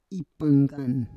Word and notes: ipanggan: Roon

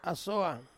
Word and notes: asoang: Ansus

